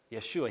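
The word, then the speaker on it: Yeshua (ישוע): Now, some of you are nodding because you realize that was how you would pronounce the name Jesus in Hebrew, Yeshua.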